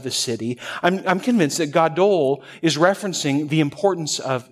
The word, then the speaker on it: gadol: And the word for great here in the Hebrew is the word gadol, okay? Gadol, G-A-D-O-L, gadol. It's actually not that in Hebrew, but I can't pronounce those letters, all right?